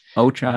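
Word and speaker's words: Ochai: Next guy I've got on my wing list is Oche Agbaji. Did I say his name right? – Ocha Agbaji, yeah, from Kansas.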